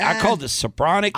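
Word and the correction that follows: Spiranac: I would say Spironak. – Spironak.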